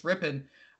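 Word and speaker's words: Rypien: That's how they pronounce his name, is just Rippon.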